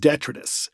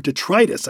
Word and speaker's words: detritus: My biggest word mispronunciation, I just mispronounced it too, is detritus. I always pronounce it as detritus. And detritus is a word, or detritus, I should say.